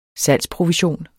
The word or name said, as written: salgsprovision